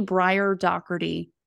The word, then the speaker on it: Briar Dougherty: I'm the only Briar Dougherty on LinkedIn.